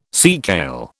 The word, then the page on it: seakale: British English